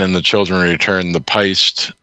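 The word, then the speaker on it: péist: And I'm pretty sure I got that pronunciation right. This word is P-E-I-S-T-E. Yeah. So that's an Irish word for like a beast or a monster, you know.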